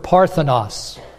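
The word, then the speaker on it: parthenos (παρθένος): It's the Greek word parthenos. That's how we pronounce it.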